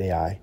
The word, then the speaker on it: Ai: Joshua sent men from Jericho to Ai, and I did learn as best I could that that's basically how you say it, Ai, which is near Beth Aven, east of Bethel, and said to them, "Go up and spy out the land."